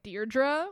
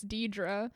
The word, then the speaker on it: Deidre: And it's Deidre, which is interesting because, and this isn't interesting, I just wrote how Mary Alice pronounces it like Deirdre when it's Deidre. – Deirdre. Yeah, because there's no other R in it, right?